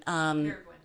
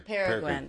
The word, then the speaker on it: Peregrine: Peregrine. – Yeah. – Peregrine. – Peregrine. That's it. Peregrine.